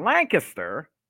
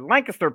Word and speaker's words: Lancaster: Saturday, April 29th at the Brightside Opportunity Center in Lancaster, not Lancaster, Lancaster, Pennsylvania. – Yeah, one syllable.